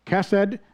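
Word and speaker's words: chesed (חסד): Today, we're looking at the ancient Hebrew word chesed, or hesed, pronounced chesed, it's a masculine noun, by the way.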